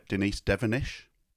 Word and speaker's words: Devenish: Denise Devenish? – Yes.